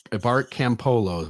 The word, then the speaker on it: Campolo: I'm going to pronounce his name, Bart Campolo, right? Mm-hmm. Okay.